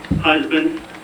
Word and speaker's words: Husband: Mike Husband. Last name is spelled H-U-S-B-A-N-D.